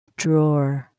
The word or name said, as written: drawer